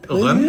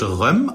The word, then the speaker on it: Rømø: Rømø, ich glaube, es wird Rømø ausgesprochen. Rømø, ich bin jetzt auch nicht dem Dänischen mächtig, aber ich glaube, ich hörte hier schon sehr oft, dass man Rømø sagt zu der Insel und das letzte für uns Ö eigentlich unterschlägt.